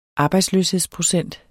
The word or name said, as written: arbejdsløshedsprocent